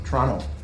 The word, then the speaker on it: Toronto: Toronto. – Toronto. Canadians don't pronounce the T.